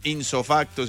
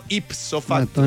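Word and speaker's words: ipso facto: No, no, no se dice de no. Insofacto, sino ipsofacto.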